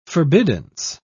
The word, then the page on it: forbiddance: British English